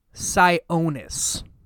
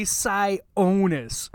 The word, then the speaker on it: Sionis: It's S-I-O-N-I-S. Logically, I would think Sionis. No, Sionis.